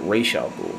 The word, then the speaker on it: Ra's al Ghul: Because I've always heard In the comics and the animated series, they pronounce Ra's al Ghul. And I think even in Arabic, that where that name comes from, that's how it's pronounced.